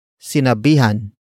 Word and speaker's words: sinabihan: Word for word it's sinabihan mo na ba siya.